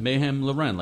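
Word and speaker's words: Mayhem Lauren: Did I say that right? Mayhem Loren? – Mayhem Loren, like Ralph Lauren.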